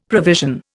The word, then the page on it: provision: Indian